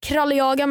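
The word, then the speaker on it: Craliaga: Ja, på Instagram så heter jag Kralljaga med C. Så C-R-A-L-I-A.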